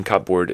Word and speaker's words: cupboard: So, for example, if you say cupboard, the P in cupboard is silent. So you spell it as cupboard, but you don't pronounce the P at all when you pronounce cupboard, right?